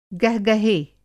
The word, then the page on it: गहगहे: Hindi